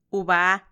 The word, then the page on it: ubaa: Macuiltianguis